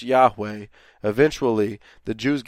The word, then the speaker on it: Yahweh: We say Yahweh as maybe a close pronunciation of what it would be.